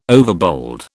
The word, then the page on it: overbold: British English